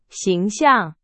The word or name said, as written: 形象